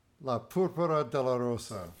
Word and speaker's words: La Púrpura de la Rosa: Well, let's talk a little bit about, and I hope I get this pronunciation correct, La Púrpura de la Rosa. – Bravissimo. – Is that good? – Very good. Perfect.